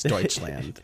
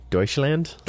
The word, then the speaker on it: Deutschland: That's how you say it in Spanish. It's not how they say it there. It's Deutschland there. – Ah. Is that how you pronounce it, too? Deutschland? – Deutschland, yeah.